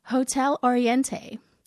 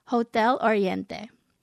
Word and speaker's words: Hotel Oriente: I was listening back to the episode and I realized that I had pronounced one of Jennifer's books as Hotel Oriente. To all the Filipino listeners of the podcast, I am sorry. I should have been saying it properly. Hotel Oriente.